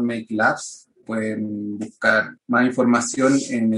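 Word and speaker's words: Meik Labs: Eh, make Labs es como una españolización de make, de crear, y por tanto se escribe como se pronuncia en español con M-E-I-K. No, no como se escribe correctamente en, inglés.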